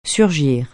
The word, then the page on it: surgir: French